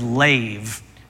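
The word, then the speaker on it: lave (לב): The Hebrew word for heart is lave. It's basically the Hebrew letter for L, the Hebrew letter for V, and then we know that you fill it in with a long A sound, so it's lave.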